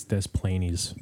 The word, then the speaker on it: Des Plaines: Is it DePlain? Am I saying that right? – I want to say it's Des Plaines. – Des Plaines? No. – That's I think people call it Des Plaines.